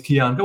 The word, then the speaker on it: Kian: I'm like, my name is Kian Gohar. And when they can't pronounce it correctly, I'm like, it's like Keanu without the ooh.